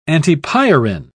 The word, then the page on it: antipyrin: British English